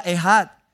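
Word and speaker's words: ehad (אחד): La palabra que se traduce allí, uno, es una palabra hebrea que se pronuncia ehad.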